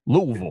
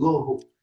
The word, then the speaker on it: Louisville: It's the vul. Louisville. – It's like almost one syllable, barely two. Louisville.